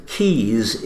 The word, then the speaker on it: Caius: And the Keys is spelt oddly, isn't it? C-A-I-U-S. Is that Latin? – It is.